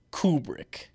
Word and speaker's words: Kubrick: Are you English or American? – Neither. – Okay. Well, I mean like. – Yeah, I'm American. – So, we say Kubrick. – Uh-huh. – I'm pretty sure it's people England. It's pronounced Kubrick. – So Kubrick. – Kubrick. – Stanley Kubrick.